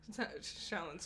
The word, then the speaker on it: Shaolin: Shaolin Soccer. – Why did I say it like that? – It's the SH and then the S.